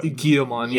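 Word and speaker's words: Guilmon: You don't emphasize like the L's. What? Guillemon.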